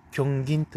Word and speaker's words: 狂言: えー、っとですね、えー、八重山のですね、芸能で、えー、漢字で狂言と書いて、狂銀というものがあります。